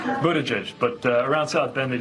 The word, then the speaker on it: Buttigieg: So the way to say Buttigieg, I didn't know at first, did you? Well, they made a big deal out of it. Boot, edge, edge. Yeah, that's how you say it.